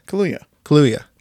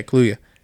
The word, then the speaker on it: Kaluuya: Daniel Kaluuya? How do you say it? – Kaluuya. – Yeah. Okay. Yeah, Kaluuya.